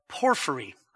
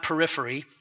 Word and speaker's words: Porphyry: So although modern scholars follow the lead of Porphyry, I mispronounced it for those who were listening to me earlier, not periphery, as though beating around the bush, P-O-R-P-H-R-Y, who in the 3rd century A.D.